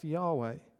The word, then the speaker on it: Yahweh: An A and an E were added to help us to be able to pronounce this in the West, Yahweh.